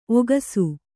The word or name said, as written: ಒಗಸು